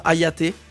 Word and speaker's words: Hayate: Première soirée le jeudi soir avec, je pense qu'on prononce comme ça, Hayate. – Absolument.